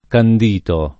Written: candito

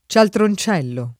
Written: cialtroncello

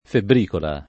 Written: febbricola

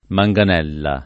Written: manganella

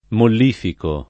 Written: mollificare

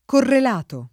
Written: correlato

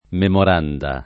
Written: memorandum

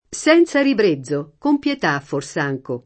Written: fors’anche

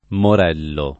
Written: morello

